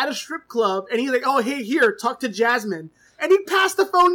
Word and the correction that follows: Yasmeen: It's pronounced Yasmeen, first of Yasmeen, all. Yasmeen, yes.